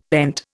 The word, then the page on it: pent: Indian